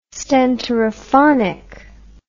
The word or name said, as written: stentorophonic